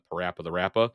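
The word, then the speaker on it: PaRappa the Rapper: You got Parappa the Rappa. – Yeah, I said it right on this episode.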